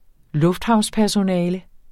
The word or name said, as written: lufthavnspersonale